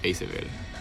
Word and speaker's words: Isabel: Pues realmente mucha gente lo pronuncia de formas diferentes, pero o sea, yo lo pensé como Isabel. Pero de cualquier forma es bienvenida siempre.